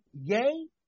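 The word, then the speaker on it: Ye: How do you say his name? Yay as in Kanye West.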